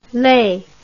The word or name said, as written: les